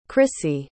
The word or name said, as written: krissy